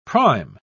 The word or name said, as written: prime